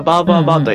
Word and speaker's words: 333: ちなみにベトナムのさっき言ったバーバーバーという 333 と書いてバーバーバーと読む